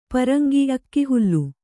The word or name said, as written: ಪರಂಗ